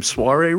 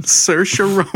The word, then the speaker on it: Saoirse: Soiree Ronan. – Yeah. No, party Ronan. – Saoirse Ronan. And I misspell it on all our docs now because I have to. Otherwise, I'll say soiree like a dickweed.